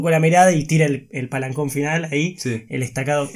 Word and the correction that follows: estocada: en la, estocada. La, pronunciación. La estocada. La estocada final, gracias, Fer.